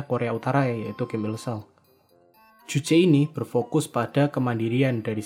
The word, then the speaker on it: Juche: Saya pernah dengar ya beberapa konten kreator nih salah bacanya dari jus. Bukan ya, bener Juche ya.